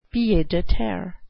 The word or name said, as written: pied-a-terre